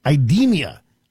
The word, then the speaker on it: IDEMIA: The company in question, I believe, is pronounced IDEMIA, I-D-E-M-I-A.